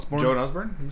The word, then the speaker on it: Joan Osborne: Joanne Osborne? – Joan Osborne? – Yeah.